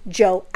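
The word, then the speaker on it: Djo: Which, bitch, I never even knew it was just pronounced as Joe. I thought it was, like, D-Jo or some shit like that.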